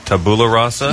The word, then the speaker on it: tabula rasa: You mentioned the phrase tabula rasa. – Yep. – Saying that correctly? – Yep.